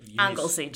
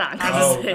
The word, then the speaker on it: Anglesey: Anglesey. Just call it Anglesey.